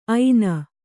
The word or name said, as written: ಐನ